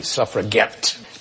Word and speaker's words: suffragette: In a feminist move, the Women's Social and Political Union insisted on saying this word with a hard G, as in, we'll get the vote. Nolan. – What is suffrage? – No. – What is suffragette? – Yes, we'll accept that.